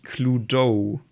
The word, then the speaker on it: Cluedo: Und die korrekte englische Aussprache ist tatsächlich Cluedo. Also mit diesem O am Ende, was halt, ne, wie wir gerade herausgefunden haben, die englische, dieser englische Akzent im Lateinischen ist.